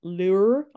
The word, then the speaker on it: Loehr: Lehrer. I can't ever say his last name, but it's spelled L-O-E-H-R.